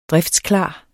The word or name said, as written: driftsklar